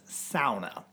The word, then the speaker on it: sauna: Find yourself a sauna. Or sauna. – No, you're pronouncing it right. – Here in Wisconsin, but I believe. – Yeah. – In Finland, it's sauna. – No, that's the correct.